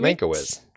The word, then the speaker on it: Mankiewicz: How do you say it? Mankiewicz? – Mankiewicz. Just Mankiewicz.